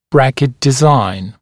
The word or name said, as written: bracket design